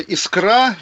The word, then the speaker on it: Искра: Издание «Искра» с ударением на «А» провело расследование, и, в общем, вот так сюрприз.